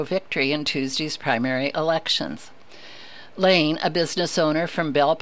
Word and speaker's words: Lahn: Lane, I think is how it's pronounced, L-A-H-N, upends Feastra in primary.